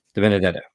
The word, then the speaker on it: DiBenedetto: Now, DiBenedetto.